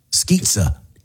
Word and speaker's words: Skizza: I think it's pronounced Skizza. – Pizza, like pizza, like it rhymes.